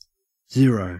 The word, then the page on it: zero: Australian English